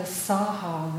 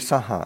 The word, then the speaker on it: saha: It seems like we stretch out the saha, though. That saha. Well, it should be saha. – It should be just short. – Ti sarane na saha silani. So that it should, it's later, pronounce it properly, would be it'd be two short syllables.